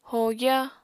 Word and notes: hogya: apatani

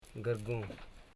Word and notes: gurgo: gur.go

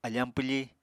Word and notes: afañ pwfi: Apatani

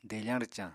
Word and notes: defañ-rwcañ: apatani

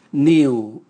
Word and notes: niu: Raga

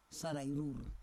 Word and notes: sarai rur: Roon

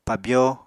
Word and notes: pabyó: Apatani

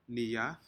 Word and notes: niad: Aneityum

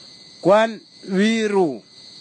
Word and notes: kwanviru: Kwamera

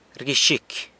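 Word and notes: rguixicy: San Lucas Quiaviní Zapotec

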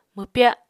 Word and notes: mwpya: Apatani

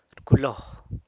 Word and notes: rculo: sanlucasquiavini

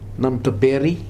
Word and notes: Namtaperi: Futuna

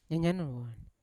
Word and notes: nyanya nowon: Yawa

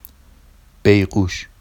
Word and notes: bejɢuʃ: Vafsi

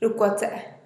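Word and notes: rukwatse'e: Teotitlán del Valle Zapotec

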